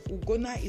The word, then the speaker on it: Ugonna: Ugona is spelled U-G-O-N-N-A.